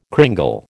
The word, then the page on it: cringle: American English